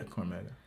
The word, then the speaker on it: Cormega: Cormega. – Yes. – Did I say it right? – Yeah, Cormega.